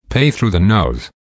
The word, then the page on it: pay through the nose: American English